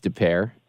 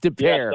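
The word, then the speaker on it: Des Peres: It looks like it's spelled Des Perez. D-E-S. – Des Perez. – But it's DePere.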